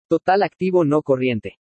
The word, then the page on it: TOTAL ACTIVO NO CORRIENTE: spanish